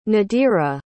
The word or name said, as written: nadirah